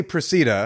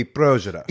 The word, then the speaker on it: Procida: We say Procida. – Okay, but in Italian it would be Progera.